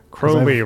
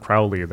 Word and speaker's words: Crowley: Crowley rhymes with unholy, not Crowley. That rhymes with Fowley.